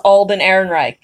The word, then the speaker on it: Alden Ehrenreich: And the problem is, though, it's almost hard to see him interact with Alden Ehrenreich.